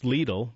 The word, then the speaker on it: Lidl: And excuse me, that's pronounced Lidl. It's the grocery store.